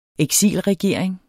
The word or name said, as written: eksilregering